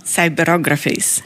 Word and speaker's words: Cyberographies: KLOF heißt das, also K-L-O-F, Cyberographies of Folk, so heißt es. Oder habe ich es falsch ausgesprochen? – Cyberographies. – Ich habe es falsch. Ich wusste, dass ich es falsch betone.